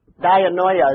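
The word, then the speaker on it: Dianoia (διάνοια): How do you say that? Dianoia is what I'd say. D-I-A-N? Dianya.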